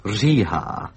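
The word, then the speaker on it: Říha: Well, it's rather lucky that the name of the clarinet soloist has one of the most difficult sounds to pronounce, a particularly Czech sound, Rziha, which is R with a little hook on the top of it.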